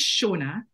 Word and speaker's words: Seonad: My name is Seonad, but it is kind of it's Gaelic, Scottish Gaelic spelling.